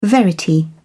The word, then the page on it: verity: British English